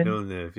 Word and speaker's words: Villeneuve: Villeneuve? Is that how you say his name? – Villeneuve, yeah.